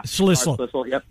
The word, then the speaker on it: Schlissel: I've seen conflicting reports, and I'm going to butcher his name in all likelihood, the president of Michigan, Dr. Schlissel. I'm close. – Schlissel.